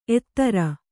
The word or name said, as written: ಎತ್ತರ